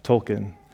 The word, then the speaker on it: Tolkien: And a part of that group is a guy named Charles Williams, another guy named J.R.R. Tolkien. Let me say that right, because that's important.